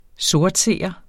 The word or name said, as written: SORTSEEREN